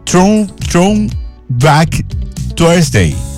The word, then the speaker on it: Throwback Thursday: Porque esto en inglés significa, o mejor dicho, se lee, se pronuncia Throwback Thursday, es decir, jueves de recuerdo.